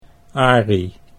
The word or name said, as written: Arie